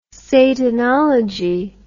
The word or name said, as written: satanology